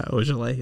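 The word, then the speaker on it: Ojeleye: It's S-E-M-I, which is Semi. – Semi Ojale? – Yeah, Ojale is how you pronounce his last name.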